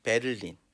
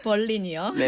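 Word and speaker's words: Berlin: 버린이라는. 우리나라 말로 얘기하면 베를린. – 아, 저도 베를린이라고 읽으려고 하다가 네. 선생님께 한번 여쭤봤는데 여쭤본 걸 잘했습니다. 네. 버린이요.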